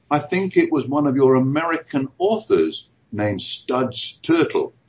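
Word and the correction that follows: Terkel: Studs Turtle. I think I've got his name right. No, Turkle. Turkle. With a C. – Yeah. With a K.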